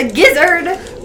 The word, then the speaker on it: gizzard: So it's the gizzards, a gizzard? – But it sounds like lizard, but it's gizzard. Pronounced gizzard. No, but it's got that's how you make the gravy, though.